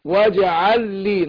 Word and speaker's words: واجعل لي: فهنطق لام واحدة مشددة، أقول واجعل لي. واجعل لي نطقت اللام واحده مشدده.